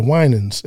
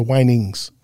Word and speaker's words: Winans: And I was coming home and I heard on the radio, they said the Winans. And the guy mispronounced, he called the Winings. Next coming up, the Whinings.